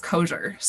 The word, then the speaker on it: Kosior: It's actually pronounced Kosior. So it's a Polish Eastern European last name. And it's a little bit elusive for some.